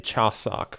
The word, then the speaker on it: Chuseok: Uh, it is the the Chuseok Festival, and I actually uh, did pronounce that right.